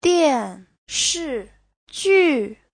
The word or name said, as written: dian4shi4ju4